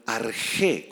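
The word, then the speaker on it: arge (ἀρχή): Este sustantivo principio, subraya esa palabrita principio, es la palabra griega arge con X y acento en la E, arge, y significa o se refiere al origen de algo, al origen de algo.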